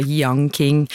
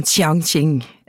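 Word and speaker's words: Jiang Qing: Vi har lagt oss på den gode norske uttalelsen som da Yang King. På kinesisk så det noe sånn som Qiang Qing.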